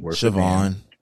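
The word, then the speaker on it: Siohvaughn: Siobhan. It's Siobhan. Yeah, it's Siobhan. – Oh, it's Siobhan, but it's spelled different. – It's spelled real Chicago, but it's Siobhan.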